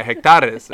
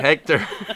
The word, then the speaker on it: hectares: I was like, oh, I mean hectares. And he's like, oh yeah, hectares. It's not Hector.